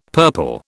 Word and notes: purple: British English

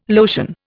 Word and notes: lotion: Indian

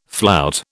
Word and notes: flout: British English

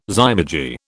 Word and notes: zymurgy: British English

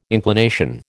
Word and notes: inclination: American English

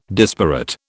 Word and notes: disparate: British English

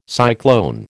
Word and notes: cyclone: American English

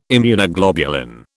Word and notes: Immunoglobulin: British English

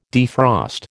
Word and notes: defrost: American English

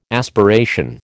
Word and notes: aspiration: American English

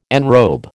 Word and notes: enrobe: American English

